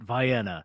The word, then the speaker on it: Vienna: I think of Vienna, we pronounce it Vienna.